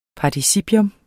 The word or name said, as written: participium